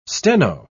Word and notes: steno: British English

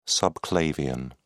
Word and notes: subclavian: British English

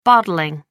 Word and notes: bottling: American English

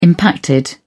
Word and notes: impacted: British English

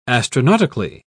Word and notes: astronautically: British English

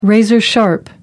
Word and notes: razor-sharp: American English

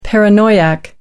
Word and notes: paranoiac: American English

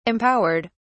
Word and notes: empowered: British English

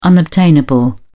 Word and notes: unobtainable: British English

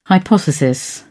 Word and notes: hypothesis: British English

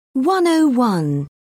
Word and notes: 101: British English

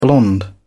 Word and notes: blonde: British English

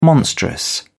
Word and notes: monstrous: British English

